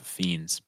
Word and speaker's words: Fiennes: Ralph Fiennes, yeah. I think that's who you're talking about. You said Ralph Fiennes, but I think you mean Ralph.